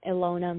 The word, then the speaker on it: Ilona: So, but my Instagram handle is I am Ilona Music, and my name is spelled Ilona.